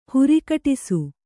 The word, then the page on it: ಹರೆಗಡಿಸು: haregaḍisu